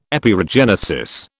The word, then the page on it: Epeirogenesis: British English